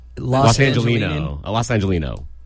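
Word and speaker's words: Los Angeleno: And I'm not a big fan of Sacramento as a Los Angelonian. But that being said, I don't think I said that right. That being said, Los Angelino.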